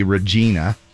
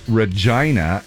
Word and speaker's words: Regina: A lot of people think that it would be Regina, but it is actually pronounced Regina.